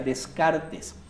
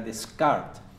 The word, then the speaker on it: Descartes: En realidad en español se pronuncia Descartes. Si quisiéramos pronunciarlo en francés sería Descartes. Pero bueno,